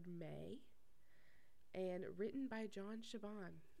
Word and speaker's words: Chabon: Which I also listened to the Supernatural Then and Now podcast about this episode, and they say John Chabon as well. So we've been saying it right, besties.